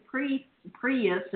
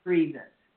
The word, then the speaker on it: Priebus: And then last night behind curtains, he sticks Steve Bannon up the chief's the staff and that rancid Prius or whatever the frig he is. I can never pronounce it. Rancid Priebus. – R-E-I-N-C-E, yeah.